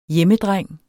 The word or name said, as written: hjemmedreng